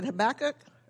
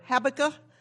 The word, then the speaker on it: Habakkuk: You can pronounce it Habakkuk or Habakkuk.